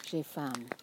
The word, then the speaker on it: faim: J'ai faim. – Is that what it is? – Yeah. – J'ai faim. – No, j'ai faim. – J'ai faim. Mm-hmm. How do you spell it, faim? – F-A-I-M.